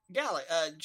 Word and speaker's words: Galaga: Galaga. G-A-L-A-G-A. Yes. Galaga. – G-A-L-A-G-A.